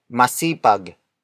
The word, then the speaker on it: masipag: The next word is masipag. Masipag. Masipag. Masipag is spelled as M-A-S-I-P-A-G.